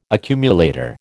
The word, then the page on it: accumulator: American English